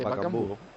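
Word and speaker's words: Bakambu: Es que se dice Bacambu.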